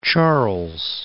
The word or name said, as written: Lake Charles